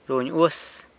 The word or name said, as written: runy uas